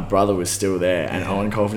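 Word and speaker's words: Eoin Colfer: Did you ever read Owen Colfer? – Oh, I love Owen Colfer. Artemis Fowl, worst bloke. Best books. – Dude, I mispronounced like two of those four words you just said. – Yeah. – Like as a kid just reading it, I was like, Eon.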